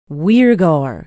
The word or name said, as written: weirgor